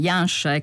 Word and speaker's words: Janša: Noi abbiamo parlato anche ai microfoni di Radio Tremondo di quello che sta accadendo, insomma, di come in realtà questo governo di Janša, ecco, ci ha insegnato Matteo Bonomi a pronunciarlo bene, il governo di Janša sta un po' plasmando diversamente, ecco, anche gli attori della democrazia reale.